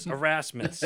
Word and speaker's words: Erasmus: Erasmus.